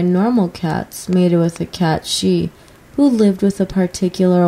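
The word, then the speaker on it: cat sìth: I refer to him as a Sith cat, but I think the original Scottish or Irish pronunciation is cat she.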